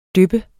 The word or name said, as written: dyp